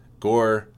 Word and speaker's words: Ngor: But I had a chance to meet some of these guys, and Gore, I mean, it's spelled N-G-O-R, but it's Gore is how you pronounce it.